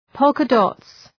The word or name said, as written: polka dots